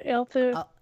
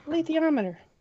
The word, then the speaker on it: Alethiometer: Alethiometer, is that how you pronounce it? – Alethio. – That's it. That's better. Alethiometer.